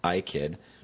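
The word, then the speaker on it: Ikhyd: Sources in New York Thursday confirmed an earlier report that the boy's father, Benjamin Bromfrom, had obtained an order preventing the youngster, his first name is pronounced I-Kid,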